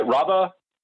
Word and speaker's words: Raba: Raba? – Yes.